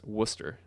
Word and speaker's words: Worcester: And he says it's pronounced Worcester, which, you know, in the California accent that we both sport, that vowel sound just isn't used. – It's Worcester. – It's Worcester. – Worcester. – Yeah. It's not Worcester.